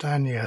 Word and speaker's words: Agnes: Now, somebody told me that they're using an it's pronounced Anja Sobel. It's spelled Agnes, but she's Danish.